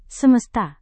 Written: semesta

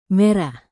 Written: merah

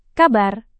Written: kabar

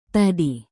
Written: tadi

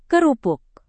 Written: kerupuk

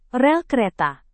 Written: rel kereta

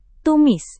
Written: tumis

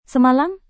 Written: semalam

